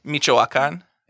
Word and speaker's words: Michoacán: Michoacán.